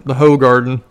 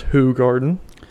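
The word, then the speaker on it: Hoegaarden: That's a beer, by the way. I think it's pronounced hoe garden.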